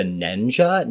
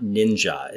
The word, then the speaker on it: Nenja: The older monk was known as the Nenja, not Ninja. It's spelled N-E-N-J-A.